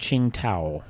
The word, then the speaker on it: Tsingtao: Where is Chow Tao from? – Stop. – Yeah, it's spelled T-S-I-N-G-T-A-O. You've seen this. – Qingdao. – It's Qingdao. – It's pronounced Qingdao.